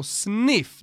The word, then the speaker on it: Sniph: Vi är sponsrade av Sniff. – Ja, S-N-I-P-H uttalas alltså Sniff.